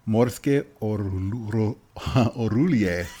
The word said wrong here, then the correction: Orgulje: Did I get O-O-O-O-O-O? – Nope. Morske Orgulje. – Orgulje. – Orgulje. – Orgulje. – So give me that accent on the first syllable. Orgulje. – Orgulje.